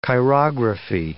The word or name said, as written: chirography